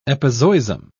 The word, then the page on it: epizoism: British English